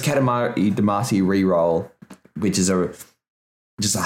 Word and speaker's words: Damacy: That's how I was going to pronounce it, but I have heard it pronounced Damarkey. – I'm not really sure. I think it's Damacy.